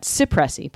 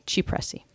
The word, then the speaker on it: Cipressi: And that's C-I-P. So a lot of people pronounce that Cipressi, but it's actually Cipressi.